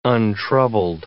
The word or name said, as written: untroubled